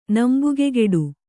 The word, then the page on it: ನಂಬುಗೆಗೆಡು: nambugegeḍu